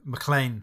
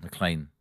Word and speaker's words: McLean: So it can't be something that, like McLean's Nose, or how do you say it again? It's not. – No, McLean. – McLean. – John McLean.